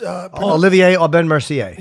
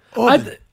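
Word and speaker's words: Aubin-Mercier: It's Olivier Aubin Mercier. – Yeah. Aubin.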